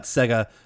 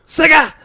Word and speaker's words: Sega: It's not Sega. I remember the commercials that go, Sega! They have to say it that way. That's how you say it.